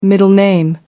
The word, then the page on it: middle name: American English